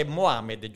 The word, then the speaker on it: Mohammed: Quindi questa è la corretta, perdonami, l'accento è Mohammed, giusto? – Sì, Yasir Mohammed.